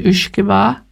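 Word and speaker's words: uisge beatha: If you can send some brandy and French wine, it would be most acceptable to the colonel, though he is slowly acquiring a taste for the ushkeba. He calls it whisky, which is the way the English pronounce the word.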